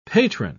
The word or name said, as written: patron